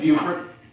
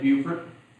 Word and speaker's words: Beaufort: Well, I always say it like this. I'm from a town called Beaufort. It was named after a Frenchman by the name of Beaufort. But it's Beaufort. And if you go there, it's Beaufort.